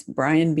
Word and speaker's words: Bryan: And it's Bryan, B-R-Y-A-N, Welsh, W-E-L-S-H.